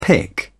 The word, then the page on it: pick up: British English